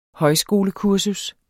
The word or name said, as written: højskolekursus